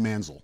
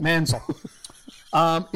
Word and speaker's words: Manziel: I think it's pronounced Manziel. – Yeah, Manziel. – Yeah, it's pronounced Manziel. – Manziel.